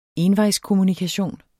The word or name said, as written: envejskommunikation